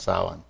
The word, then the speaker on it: Samhain: It's spelled Samhain, but it's, it's actually pronounced Samhain.